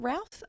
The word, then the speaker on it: Routh: Yeah, his name is Brandon Routh. Would you pronounce it Routh? R-O-U yeah, Mouth. Routh.